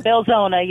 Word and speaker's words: Belzoni: Belzona, yes, is the correct pronunciation.